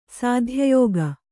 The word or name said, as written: ಸತ್ಯಯುಗ